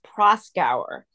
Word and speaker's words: Proskauer: But I will correct you that it's Proskauer. And it's very hard to know how to pronounce it.